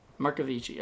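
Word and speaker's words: Marcovicci: And Andrea Marchiovi, or wait, Markovici. That's a hard name to say. – Let me see if I can pronounce that, that name? – Andrea Markovici. – Markovici, okay.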